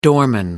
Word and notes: doorman: American English

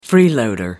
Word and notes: freeloader: American English

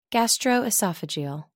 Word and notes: gastroesophageal: American English

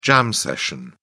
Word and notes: jam session: British English

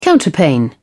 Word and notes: counterpane: British English

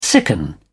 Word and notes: sicken: British English

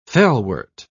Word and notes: felwort: British English

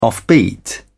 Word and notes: offbeat: British English

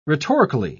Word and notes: rhetorically: British English

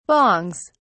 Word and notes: bongs: British English

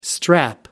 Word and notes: strap: American English

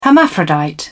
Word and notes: hermaphrodite: British English

